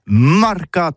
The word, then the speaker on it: marcat: Eh bien, dis ça à tous, mais le mercat de l'Uni ou le mercat des rebelles, mais en gascogne toulousain, c'est prononcé les marcat avec un A. – Marque. – En gascogne toulousain, bah, du côté, par exemple, de l'île des Baches, c'est l'île Jourdain, pour parler du marché, vous prononcez les marcat.